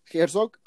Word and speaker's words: Herzog: En este estarán presentes este, Jesús Silva Cerjo, perdón, ¿cómo se, pronuncia? Jesús Silva, Herzog,